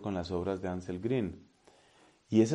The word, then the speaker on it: Grün: que se escribe G-R-U-N, la U con diéresis, entonces es esa U alemana. Anselm Grün ha estado predicando aquí en la Argentina, parece que con cierto renombre, con cierto éxito.